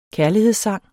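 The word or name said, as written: KÆRLIGHEDSSANGE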